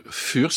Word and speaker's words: Fur: Vous avez pris comme nom de groupe Fur, ça s'écrit F-U-R. Si c'était anglo-saxon, ça serait une fourrure, et ça serait fur.